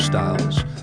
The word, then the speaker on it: Styles: Hey folks, and welcome to Storyville with Nuga's own Jeff Styles, spelled with a Y, S-T-Y-L-E-S.